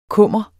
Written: kummer

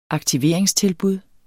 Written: aktiveringstilbud

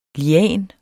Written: lian

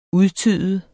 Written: udtyde

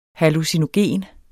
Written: hallucinogen

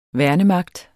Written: værnemagt

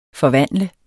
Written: FORVANDLEDES

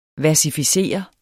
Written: versificere

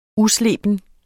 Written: usleben

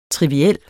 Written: triviel

